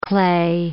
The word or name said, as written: clay